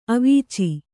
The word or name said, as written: ಅವೀಚಿ